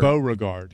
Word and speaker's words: Beauregard: And special thanks and congratulations to our Be Like Mike winner, Don Bavard. – Yeah. Stop saying his name wrong. – He's from Greensboro. – It's Beauregard.